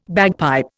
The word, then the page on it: bagpipe: Indian